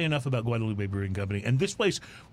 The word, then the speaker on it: Guadalupe: So it is Guadalupe, but if you're in Austin and you go down Guadalupe Street. – Yeah, that's right. – Don't pronounce it Guadalupe. They look at you weird.